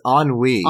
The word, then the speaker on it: ennui: Did you pronounce it NUI? – Is it not? Is it ennui? – It's ennui. – Ennui.